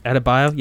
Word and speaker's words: Adebayo: I'm pretty interested to see if I want to say if I pronounce his name wrong, Adebayo. – Adebayo, yeah.